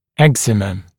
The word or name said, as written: eczema